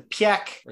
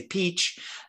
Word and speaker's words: Piëch: But kind of switching gears to another car that triggered an emotion for me on multiple levels, the Piëch, or the however you don't want to pronounce it. Some people say Peach, etc. But Piëch as in Ferdinand Piëch, the cousin, nephew, the Porsche Piëch family.